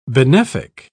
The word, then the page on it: benefic: British English